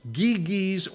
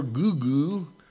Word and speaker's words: Gyges: This guy who went by the name of Gyges, Giges, or Gugu. I mean, seriously, that's how you'd pronounce the name.